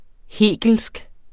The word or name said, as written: hegelsk